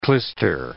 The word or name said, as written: clyster